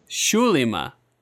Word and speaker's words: Zulima: Zulima. – Yeah, because I figured out they're Spanish, so they're pronouncing it like they're Portuguese.